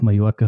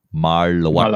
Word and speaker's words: Mallorca: Und zwar auf Mallorca haben sie ein Rennen angekündigt. Ähm, und Moment, Mo Moment. Wenn wir das aussprechen, dann bitte im korrektesten österreich-deutschsprachigen Sprachgebrauch Mallorca. – Mallorca.